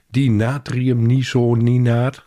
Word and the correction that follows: dinatriuminosinaat: of zoiets. Dinatriuminosinaat. Ik moet het wel even goed uitspreken.